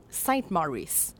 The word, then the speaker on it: Saint Maurice: Elle va souvent changer de nom, mais si on en sort une constante, c'est le nom Saint Maurice. Puis c'est bien important de le prononcer à l'anglaise.